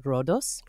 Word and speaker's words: Rhodos: On the island, I went to Rhodes, but the Greeks pronounce it Rhodos.